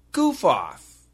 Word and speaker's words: goof off: It's G-O-O-F, goof, off, O-F-F. All right? So get that pronunciation, goof. Off. Goof off. And the F, we can connect it.